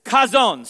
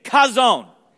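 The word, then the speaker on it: chazon (חזון): Now that word vision, the Hebrew word for vision in that is chazon. Say it with me. Chazon.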